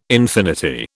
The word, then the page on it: infinity: British English